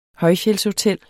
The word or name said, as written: højfjeldshotel